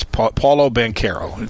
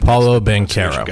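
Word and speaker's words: Paolo Banchero: People say Banchero are saying it wrong. It's Paulo Banchero. It's in their media guide. – Paulo Banchero. – Yeah, okay. So get the H out of your head.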